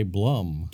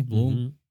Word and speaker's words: Blum: In our days working elsewhere, but we like the Blooms, or if you say Blum. – Don't say Blum. – I say Bloom. Is that how they say it over there in Germany? Bloom? Mm-hmm. – Austria? Yeah. – I think if you ask. Yeah, it's pronounced Bloom.